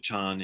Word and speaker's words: Chan: There's a Chinese character that is pronounced Chan in Chinese and Zen in Japanese,